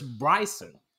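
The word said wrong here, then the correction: Björnsson: Or Thor. – That can't be right. – Bjornsson. Sorry, Bjornsson.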